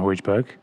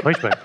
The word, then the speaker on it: Højbjerg: So bringing that creativity in midfield alongside Sissoko and Højbjerg. He obviously came on, made the impact against City. – Who? – Højbjerg. – Højbjerg. It's Højbjerg, isn't it? – I love that you pronounce Højbjerg, right? – Højbjerg.